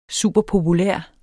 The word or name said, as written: superpopulær